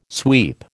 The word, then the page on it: sweep: American English